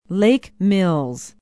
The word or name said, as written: Lake Mills